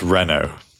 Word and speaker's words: Renault: Mercedes, Audi, Volvo, Polestar, and was it Renault? Is that how you say it? Or am I going to get made fun of for not saying it correctly? – It's Renault.